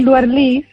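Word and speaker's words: Luerlís: Y me puso mi Luerlis y lleva un acento en la I, lo que pasa es que...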